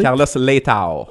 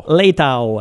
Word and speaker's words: Carlos Leitão: Donc, dans la même veine, notre ministre des Finances, Carlos Leitão, ou en tout cas, je ne sais jamais comment le prononcer. – Carlos Leitão. – Leitão. Ouais. Carlos Leitão.